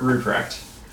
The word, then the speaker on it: Rupprecht: Yeah, so it's Kurt Rupprecht.